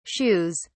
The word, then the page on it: shoes: British English